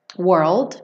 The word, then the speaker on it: world: World, não tem esse d, né? World. Que você escuta world. Muitas vezes você nem ouve o D, na verdade, né? Você pode, quando você está falando, world.